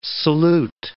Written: salute

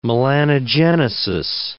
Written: melanogenesis

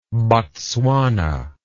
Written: botswanan

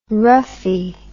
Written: roughie

